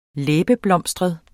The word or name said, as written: læbeblomstret